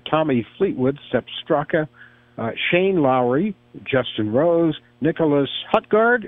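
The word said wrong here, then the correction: Nicolai Højgaard: or is that how it's pronounced? – Yeah, Nikolai Hogard, yeah.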